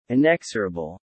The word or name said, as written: inexorable